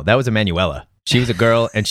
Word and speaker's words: Emanuela: I think it's just Emmanuel. – Emanuela. That's exactly how it's spelled.